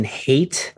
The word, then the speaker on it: Haythe: The other writer credited is Justin Haight. I think that's how you pronounce it, but it's spelled H-A-Y-T-H-E.